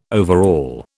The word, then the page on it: overall: British English